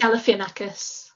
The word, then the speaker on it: Galifianakis: Galifianakis or something like that. I can't remember how his surname is pronounced. – Galifianakis.